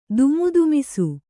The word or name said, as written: ದಿಮಿದಿಮಿಸು